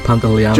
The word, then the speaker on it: Pantoliano: We could have John Leguizamo and Joe Pants, what have you pronounced his surname? – Oh, Pantoliano.